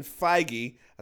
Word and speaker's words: Feige: Speaking of Avengers, before we get knee-deep into this conversation, Kevin Feige, this is the first time I've ever pronounced his name correctly, he said holding back the Avengers 4 title backfired.